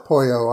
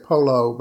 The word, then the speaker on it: pollo: I call it pollo. I've heard it say people say polo, but I like the south of the border pronunciation better.